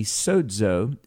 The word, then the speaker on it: Sozo: Well, Jeff Ahern is the president of Sozo Services, or maybe Sozo, if we're pronouncing it with Greek phonation, but he helps business owners and CEOs partner with God for success.